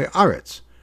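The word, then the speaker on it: aretz (ארץ): How do you say eretz when it's at the end of a phrase, at a pause, either at nachtar or pasuk? You say aretz, right? Shamayim become goes from a kamat a patach to a kamat.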